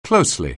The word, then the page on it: closely: anglais